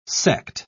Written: sect

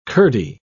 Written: curdy

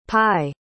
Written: pye

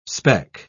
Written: spec

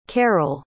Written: karol

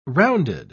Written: rounded

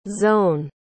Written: zoan

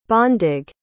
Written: bondig

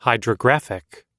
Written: hydrographic

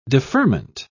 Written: deferment

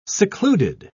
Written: secluded

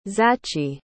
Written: zaci

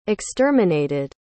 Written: exterminated

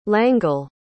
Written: langle

